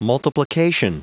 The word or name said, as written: multiplication